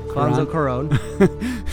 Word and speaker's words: Alfonso Cuarón: The reason I liked Roma, again, it was, so this is, I don't know how to say it, but Alfonso Cuarón. – Is it Cuarón? – Alfonso Cuarón.